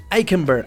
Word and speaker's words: Eichenberg: Eh, mientras tanto, vamos a estudiar ahorita a Liam Eichenberg Liam Eichenberg, Eichenberg, Eichenberg, así se pronuncia.